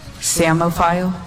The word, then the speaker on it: psammophile: But if I were, can you like wink your left eye if I were to try to put like a silent P in front of that? Like P-S-A-M-M-O-P-H-I-L-E? – Let's see. – Samophile. P-S-A-M-M-O-P-H-I-L-E, samophile. – That is correct.